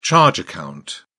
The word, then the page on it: charge account: British English